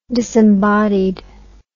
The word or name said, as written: disembodied